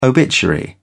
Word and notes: obituary: British English

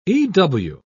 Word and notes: ew: British English

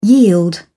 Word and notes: yield: English; British English

